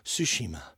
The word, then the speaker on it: Tsushima: Tsushima, which is actually is one of the fastest selling new IP I think I saw on the PlayStation. And yes, Shay's clapping because I didn't pronounce it incorrectly.